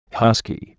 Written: husky

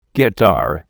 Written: guitar

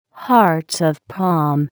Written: hearts of palm

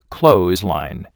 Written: clothesline

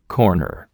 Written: corner

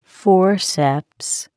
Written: forceps

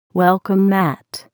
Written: welcome mat

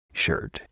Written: shirt

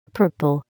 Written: purple

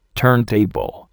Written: turntable

